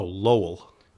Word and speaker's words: Lowell: Oh, Lowell. L-O-W-E-L-L. I thought you said like Lowell, like you were pronouncing L-O-L.